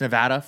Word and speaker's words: Nevada: No longer is this wrong. It's Nevada forever now. With all the exposure it's getting recently on the news, they're all saying it correctly. Nevada, Nevada, Nevada, Nevada.